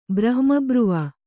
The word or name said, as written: ಬ್ರಹ್ಮಬ್ರುವ